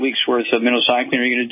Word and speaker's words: minocycline: It's pronounced like the bait fish, minocycline.